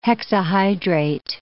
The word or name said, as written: hexahydrate